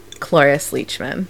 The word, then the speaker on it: Cloris Leachman: Originally, her character was, I'm probably going to butcher her name now, but it was Clarice Leachman. Is that right? – Cloris Leachman. Yep, that's, Cloris. Yeah.